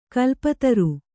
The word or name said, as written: ಖಲಪಾತ್ರ